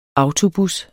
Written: autobus

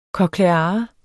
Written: kokleare

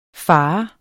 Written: farent

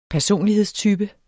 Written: personlighedstype

